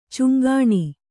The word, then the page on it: ಛಂಗನೆ: chaŋgane